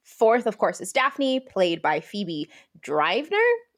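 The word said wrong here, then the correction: Dynevor: I hope I'm saying that right. – Denever. – That was very wrong. I was wrong. Phoebe Denever.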